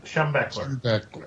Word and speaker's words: Schembechler: Schembechler.